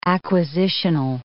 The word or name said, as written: acquisitional